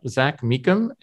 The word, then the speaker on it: Mecham: And we are thrilled to have Zach Mecham as our guest.